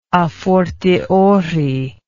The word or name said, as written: afortiori